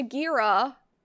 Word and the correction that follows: Chigiri: Is that how you say his name? The red haired dude? – The red haired dude? Oh, Chigiri? Yeah. – Chigiri?